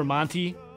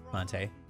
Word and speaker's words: Monte: It's like when Monte Ball told everyone how to actually say his name. Remember Monty? – Monte.